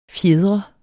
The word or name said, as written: fjedre